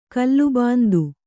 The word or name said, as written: ಕಲ್ಲುಬಾಂದು